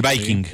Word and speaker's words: Viking: ¿Y el Viking, se dice así? – Sí, el Viking.